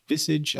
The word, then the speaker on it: visage: Ooh, visage, I think is how it's pronounced. Let's see. – Visage. – Visage. Visage. Oh, there's a Z in there that I hadn't anticipated.